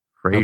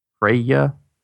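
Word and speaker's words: Freyja: Frey, well, it's F-R-E-Y-J-A. Freya. Okay. Freya. See, I would pronounce the J like a Y because Bjorn, fjords, and whatnot.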